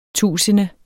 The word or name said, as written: tusinde